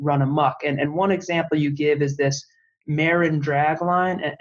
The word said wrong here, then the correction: Marion: Am I pronouncing it? The Marin drag line? – Marion.